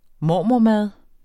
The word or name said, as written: mormormad